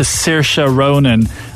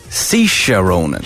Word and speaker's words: Saoirse: He has apologised to Saoirse Ronan after he tried to pronounce her name, but he did an awful job. – He did Saoirse. Saoirse Ronan.